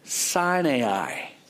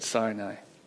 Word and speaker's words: Sinai: And he had led the flock far into the wilderness and came to Sinai. Apparently that's how you say it. I always said Sinai.